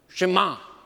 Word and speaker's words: Shema: In Hebrew, it's the word Shema. Now, it's easy to remember. In order to help you to remember the pronunciation of this word, you know, just repeat after me. Go, shh, ma, shema. Great.